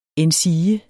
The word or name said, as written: endsige